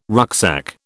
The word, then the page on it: rucksack: British English